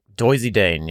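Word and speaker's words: Doisy-Daëne: Chateau Doisy Dane, maybe, is how you say it? – Doisy Dane, yeah, that sounds fancier than blend.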